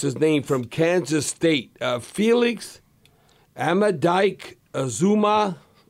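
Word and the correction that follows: Anudike-Uzomah: whatever. The defensive end from Kansas State. – Yeah, Felix Enduke Uzuma.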